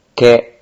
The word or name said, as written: ke